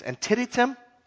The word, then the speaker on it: Antietam: In one battle, the battle of, and I don't know how to say this, Antietam, thank you.